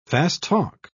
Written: fast-talk

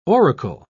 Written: oracle

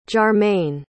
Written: jarmaine

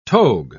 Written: togue

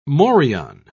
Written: morion

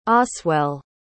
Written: oswell

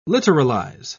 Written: literalize

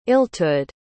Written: illtud

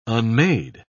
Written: unmade

